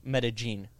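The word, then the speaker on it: Medellín: But colloquially, in there, they pronounce double L's like a hard J. Like Medellin.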